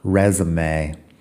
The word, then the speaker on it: resume: Yo lo escribo sin los acentos porque estamos hablando inglés y no francés, pero la pronunciación es un poco rara, resume.